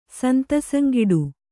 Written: ಸಂತಸಂಗಿಡು

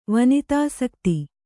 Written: ವನಿತಾಸಕ್ತಿ

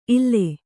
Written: ಇಲ್ಲ

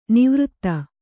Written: ನಿವೃತ್ತ

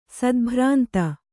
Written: ಸದ್ಭ್ರಾಂತ

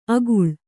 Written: ಆಗುಳ

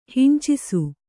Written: ಹಿಂಚಿಸು

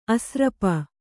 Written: ಆಸ್ರಪ

